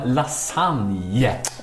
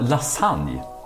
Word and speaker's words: lasagne: Vi pratar om lasagne. – Oh. – Ja, vi uttalar lasagne och inte lasagne som är brukligt där i Italien. – Jaha. – Ett franskt uttal av ett italienskt ord.